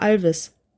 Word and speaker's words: Alois: Ich fand's nur interessant, dass ihr Alois tatsächlich Alois ausspricht, weil ich kenne das nur, ich hatte auch in der Familie einige, die so geschrieben wurden, aber mhm. das ist bei uns immer Alvis. – Alvis? Ausgesprochen. Alvis. Oh, ja, ja, ja, Das ist hier in der Gegend tatsächlich so, ja.